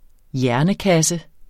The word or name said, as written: hjernekasse